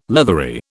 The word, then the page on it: leathery: British English